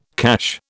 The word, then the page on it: cache: British English